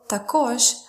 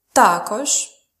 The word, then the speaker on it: також: And uh, also також has double stress. It can be pronounced as також or також. So let's see again.